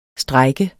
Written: STREJKERNE

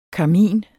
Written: karmin

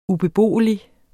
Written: ubeboelig

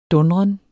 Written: dundren

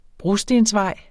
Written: brostensvej

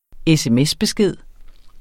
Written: sms-besked

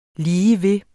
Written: ligeved

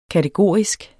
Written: kategorisk

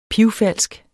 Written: pivfalsk

